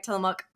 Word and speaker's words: Tillamook: Yeah, it, it, was interesting about the pronunciation because you know you see it and yeah, but yeah. you said it right, Tillamook.